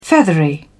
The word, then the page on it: feathery: British English